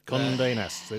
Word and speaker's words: Condé Nast: Condé Nast. It rhymes with condescending.